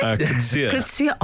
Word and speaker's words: Coetzee: Liam Gallagher responding to the fact that J.M. Coetzee, is it? – Coetzee. – Oh, lovely pronunciation. Oh, very good. – Coetzee? – Coetzee, yeah. – Okay, lovely. – Trips people up.